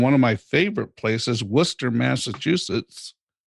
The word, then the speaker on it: Worcester: And you know, you must know something if you can look at the spelling of the city and know that it's pronounced Worcester.